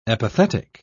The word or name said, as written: epithetic